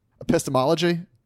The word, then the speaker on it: Epistemology: Epistemology. – Did I say that right? Sounds right.